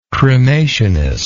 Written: cremationist

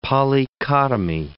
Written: polychotomy

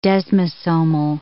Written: desmosomal

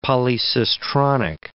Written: polycistronic

